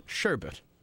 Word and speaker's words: sherbet: I put some, you know, the real pronunciation is sherbet. – Oh. – S-H-E-R-B-E-T.